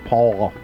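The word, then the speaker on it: pall: Pronounced P-O with a little triangle-looking thing over the top of the O and an L. – Like a pal. – So it's Paul.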